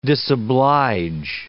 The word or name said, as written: disoblige